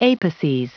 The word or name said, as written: apices